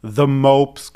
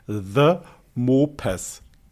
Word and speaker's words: The Mopes: Okay, also meine absolute ähm, Top-Empfehlung, wer ein Amazon Prime-Abo hat, muss unbedingt The Mopes gucken. Ich buchstabiere T-H-E neues Wort M-O-P-E-S, The Mopes, falsch ausgesprochen, The Mopes.